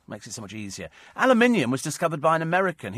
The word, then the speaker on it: aluminium: It's pronounced aluminium.